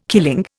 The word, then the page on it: killing: Indian